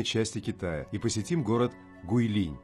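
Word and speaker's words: Гуйлинь (桂林): Гуйлинь шэн шуй джя тян ся. – Разберем название города Гуйлинь. Гуй означает «османтус» или «деревья османтуса». Произносится в четвертом тоне. А линь – это лес. Произносится во втором тоне.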